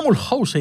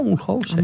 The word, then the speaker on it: Mulhouse: Voy a Mulhouse. Y le digo a todo el mundo, voy a Mulhouse.